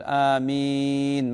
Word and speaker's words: آمين: الأمر الرابع بارك الله فيك، آمين تنطق بهكذا، تقول آمين مثلا تمد في الثانية، وبعض العلماء يقول يجوز أن يمد في الأولى، يقول آمين مثلا يمد في الأولى وفي الثانية.